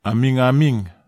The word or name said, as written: amigamig